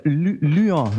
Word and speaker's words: Lyon: Also ich hab gelernt, Lyon wird es ausgesprochen.